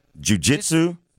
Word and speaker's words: jiu-jitsu: He is a world-renowned fitness and jiu-jitsu. Am I saying that right? – Jiu-jitsu. – Thank you. – Yes, jiu-jitsu.